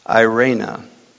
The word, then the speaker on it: eirene: Okay, and that is 15:15, and that is I'll just spell it for you: E-I-R-E-N-E, Irena.